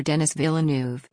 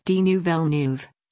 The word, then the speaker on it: Villeneuve: The announcers mispronounced the names of Dune director-screenwriter Denis Villeneuve, Denis Villeneuve, and Belfast actor Kieran Hines, Corinne Hens.